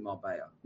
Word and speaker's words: Marbella: Marbella, I think is how you pronounce it. Marbella, see, he's on it. – Yeah, but you pronounce it Marbella. – No, you don't. How can it be Marbella there's two L's? – Pronounce Marbella. That's how it's pronounced.